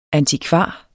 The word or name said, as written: antikvar